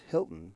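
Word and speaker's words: Hylton: I think it's spelled or pronounced Hilton, but it's H-Y-L-T-O-N. So Hilton or just Hilton? – Probably. We'll say Hilton.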